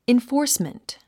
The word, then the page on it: enforcement: infɔ́ːrsmənt; インフォースメント